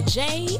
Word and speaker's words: Jaie: This is your girl, Jay, and welcome to the J Spot, and that J is spelled J-A-I-E.